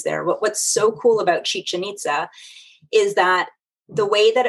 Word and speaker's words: Chichen Itza: Pyramid of, I want to say Chichen Itza.